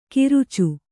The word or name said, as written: ಕರಿಚು